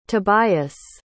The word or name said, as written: tobias